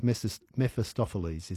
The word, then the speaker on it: Mephistopheles: Just speaking of sacrifices, we know that the it's Mephisto and it's Mephistopheles is how you say it.